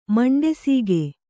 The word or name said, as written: ಮಂಡೆಸೀಗೆ